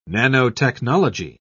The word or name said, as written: nanotechnology